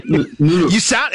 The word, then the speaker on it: Mørk: She was trying to teach me how to pronounce it. It's kind of like Mørk.